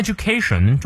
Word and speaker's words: education: An education，an，注意啊，我们元音前呃要念 an，an education，这里可以有连读，an education，an education，重音在第二个音节，is not so much about，about，重音在第二音节，注意双音，making a living。